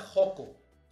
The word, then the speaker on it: Xoco: Pero los mexicanos, pues nos quedamos con la grafía X y con el sonido, perdón, con el sonido de J, pero con la escritura del sh. Entonces, es por eso que ahora en México pasan cosas como la colonia Joco y se escribe Xoco, o Xalapa y se escribe Xalapa, ¿no? Entonces el Xalapa o el Oaxaca, pues es Oaxaca.